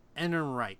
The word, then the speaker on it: Ehrenreich: It's Alden Ehrenreich.